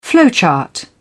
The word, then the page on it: flowchart: British English